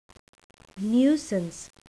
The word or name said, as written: nuisance